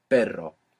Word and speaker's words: perro: r-r-r-r English sound. But you pass a little bit of air over it and you let that flick it. Perro.